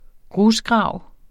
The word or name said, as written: GRUSGRAVEN